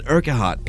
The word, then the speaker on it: Urquhart: It's cool heart entry. Sorry, I'll say that properly. That was Ryland Urquhart playing Inch Bar later tonight at 7:30 p.m.